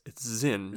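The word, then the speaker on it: Sinn: I mean, it's spelled Sin, but it's Zinn, yeah? – Zinn, I believe so. Yeah, I believe. I'm probably still going to say Sin sometimes, so whatever.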